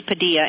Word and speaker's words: Padilla: I spoke to one of his lawyers, and evidently at one point he thought it might be less prejudicial if he didn't pronounce it the way it should be pronounced, but it is Padilla, and I think I might have corrected you once on your program.